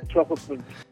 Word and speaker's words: Swakopmund: Yeah, Swakopmund.